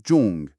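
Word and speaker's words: جونگ (钟): کلمه ساعت یعنی جونگ. تلفظش خیلی شبیه به عبارت سونگ جونگ هست.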